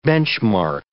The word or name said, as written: benchmark